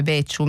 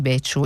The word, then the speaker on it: Becciu: mi segnala che sbagliavo a pronunciare da sarda il, il cognome Becciu, mi dice con, con, una C Becciu, io spero di,